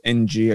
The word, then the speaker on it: Ng: Kim Ng, I believe is how you say her last name. It's just NG. I believe it said Ng.